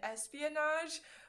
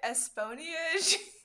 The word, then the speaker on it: espionage: So it was like something and espionage, but I was pronouncing it esponiage.